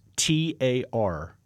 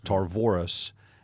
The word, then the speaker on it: Tarvoris: His name is Tarvoris, Tarvoris.